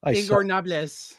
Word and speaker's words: Ingobernables: Ingobernables.